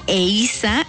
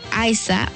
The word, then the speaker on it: Eiza: Y bueno, la actriz mexicana pausó sus entrevistas para aclarar que su nombre se pronuncia Eiza y no Aiza o Aisha.